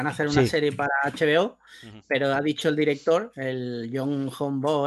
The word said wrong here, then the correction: Bong Joon-ho: Bong Joon-ho ha dicho que no va a ser exactamente un remake, sino que va a ser como inspirado.